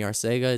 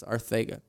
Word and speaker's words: Arcega: Well, I can't just say one word with a lisp, so he's like, I understand if people call me Arcega, it's not going to offend me, but, you know, my family's proper pronunciation is Arthaga.